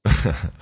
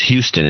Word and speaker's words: Houston: Houston. – That's the way it'd be pronounced in New York. – Really? – A lot of people don't realize it's called Houston Street, but it's Houston in Texas and Houston in New York.